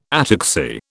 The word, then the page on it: ataxy: British English